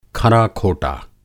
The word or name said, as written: खरा-खोटा